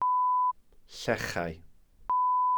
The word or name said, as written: llechau